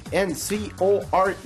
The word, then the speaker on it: encore: 예를 들어 ENCORE 뭐죠? 본토 발음으로? – Encore. – 이지만 한국어 표기는 앙코르입니다.